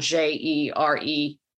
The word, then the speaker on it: Jeremias: So it's Jan Jeremias, and my last name is spelled Jeremias.